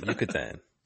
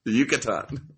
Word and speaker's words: Yucatan: What the fuck is Vukatan? How do you say it? – Yucatan. – Yucatan.